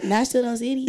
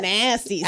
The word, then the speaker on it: National City: Did I say it like that? National City. – Yeah, I'm like, all right. – She said nasty city.